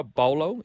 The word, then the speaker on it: Paulo: Is it Junior Bolo? Is that it, the correct pronunciation? – Bolo is, the correct pronunciation.